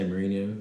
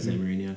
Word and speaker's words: Mourinho: It had Jose Mourinho, which I thought, Mourinho, I should say.